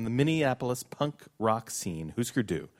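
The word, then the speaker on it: Hüsker Dü: If you pronounced all the umlauts, you would say Husker Du.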